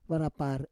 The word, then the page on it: varapar: Roon